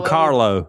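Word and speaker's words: Kahlo: It says, do you really believe that Frida, how do you say that last name? – Frida. Carlo. – Carlo, yeah.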